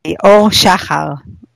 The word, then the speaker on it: Or Shahar: Or am I pronouncing your surname correctly? – Yeah, that's not that easy. Or Shahar would be the right pronunciation, but it's awesome. Very hard, I know.